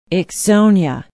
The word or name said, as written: ixonia